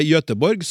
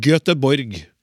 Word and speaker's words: Göteborg: På samme måte uttaler vi det Göteborg som på svensk, og ikke Göteborg Eh, eh, ja.